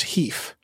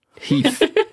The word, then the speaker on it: HEIF: which they revealed that as a company, they pronounced Heef. – Heef. – So H-E-I-F, which I get why you would head towards Heef, but they said it on stage and then they were all saying it.